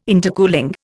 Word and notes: intercooling: Indian

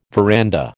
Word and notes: veranda: American English

